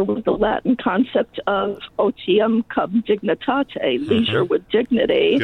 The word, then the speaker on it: otium cum dignitate: That people, I'm probably mispronouncing it. – Nope, that's pretty good. – Okay. That's not in my Black's Law dictionary. – otium cum dignitate, leisure with dignity, I guess, would be a rough translation.